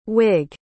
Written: wigg

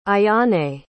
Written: ayane

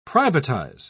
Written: privatize

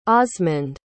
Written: osmond

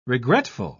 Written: regretful

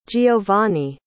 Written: geovanni

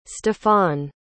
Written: stephane

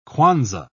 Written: kwanza

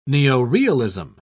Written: neorealism

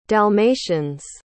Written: dalmatians

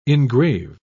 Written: engrave

